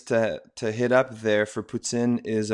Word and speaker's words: poutine: And eat poutine. No, I said it wrong again. – Oh, poutine. – That's it. There we go.